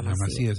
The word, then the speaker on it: Masía: Bueno, contundente la respuesta de Messi a los rumores de la partida de su hijo Thiago a la Masia de Barcelona. – La Masía se dice. Sí, tenés razón, tiene un acento en la I.